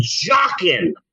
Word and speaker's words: Joughin: It's the story of Charles Jochen. – Okay. – The last name is spelled J-O-U-G-H-I-N, but apparently it was pronounced Jochen.